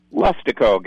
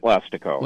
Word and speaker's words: Leistico: Leistico, Gary at Leistico. – Well, I tell you what, I said Leistico, but I like your pronunciation better than mine. L-E-I-S-T.